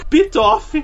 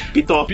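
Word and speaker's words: Pitof: O filme foi dirigido por Pitoff. É isso mesmo, eu não falei errado. O nome do diretor do filme é Pitoff.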